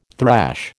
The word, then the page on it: thrash: American English